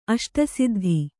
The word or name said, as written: ಅಷ್ಟಸಿದ್ಧಿ